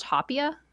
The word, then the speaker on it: hopia: And then the, is it pronounced hopia? – Hopia. – Hopia. – Well, we think. – Hopia. Yeah, hopia.